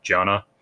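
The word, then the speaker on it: dhyana: Meditation is known in Sanskrit as jhana. That's D H Y A N A, but it's pronounced sort of like a J, jhana.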